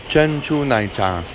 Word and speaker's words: 珍珠奶茶: 珍珠奶茶.